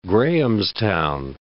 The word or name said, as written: Grahamstown